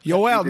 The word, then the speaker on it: Yoel: Yoel, not yo.